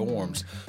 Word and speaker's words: Worms: Now, it's spelled Worms, but you pronounce W's as V's, so the Diet of Worms, which was an imperial assembly gathered to deliberate Martin Luther's teaching.